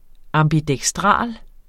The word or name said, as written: ambidekstral